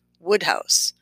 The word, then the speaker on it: Wodehouse: Quick parenthetical, I remember a long ago Masterpiece Theater preface in which the great Alistair Cooke informed me that it was spelled Wodehouse, pronounced Woodhouse.